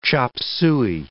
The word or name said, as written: chop suey